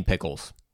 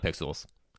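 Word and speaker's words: Piklz: And you mean pickles, not pixels. Invisible Scratch Pickles. It sounds like it should be pixels.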